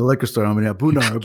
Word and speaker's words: A’bunadh: I still can't pronounce it right. – Yeah. Whatever. – I'm going to go pick it up later. – It's A’bunadh. – A’bunadh.